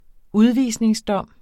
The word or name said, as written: udvisningsdom